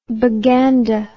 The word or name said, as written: baganda